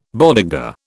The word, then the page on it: bodega: British English